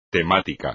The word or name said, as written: temático